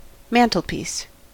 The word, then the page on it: mantelpiece: American English